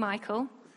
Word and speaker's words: Michal: Is it Michal? Everyone else? Consensus? Should be pronounced Michal? I thought Michal might be confusing because it sounds like a man. But oh, okay, all right. Michal it is from now on.